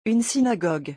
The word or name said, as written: une synagogue